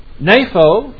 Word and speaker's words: nepho: And we have the Greek word nepho, N-E-P-H-O. That's a eta there, long E.